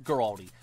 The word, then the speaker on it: Guaraldi: It's a Vince Guaraldi trio, like original. Giraldi? Giraldi. I pronounced that man's name wrong every time.